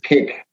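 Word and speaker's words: kék: It's pronounced like a piece of cake, cake, mm-hmm. but it's actually K-E with an accent, K, and it's pronounced cake.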